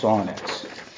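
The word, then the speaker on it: syrinx: Songbirds have a unique singing body part near their heart called a sonnex, similar to a larynx, but it's pronounced sonnex.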